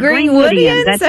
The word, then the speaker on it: Greenwoodians: I don't know how you would say that. What's the word? – Greenwoodians.